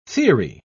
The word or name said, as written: theory